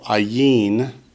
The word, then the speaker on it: ayin: The next word accents the last syllable, ayin.